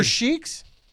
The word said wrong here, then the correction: Sikhs: How do you say that? – Sikhs.